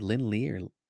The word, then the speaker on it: Lin Lee: Lin Lee, formerly Swordmaster. Am I saying that right? Lin Lee? – Yep.